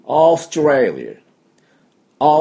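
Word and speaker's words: Australia: だからおーって伸ばしてオーストラリア、オーストラリアというふうに発音します。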